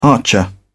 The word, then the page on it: archer: British English